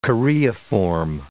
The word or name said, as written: choreiform